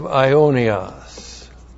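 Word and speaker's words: ionios (αἰώνιος): Modified by the adjective ionios. A-I long O-N-I-O-S. And that's the adjective that means without end or eternal.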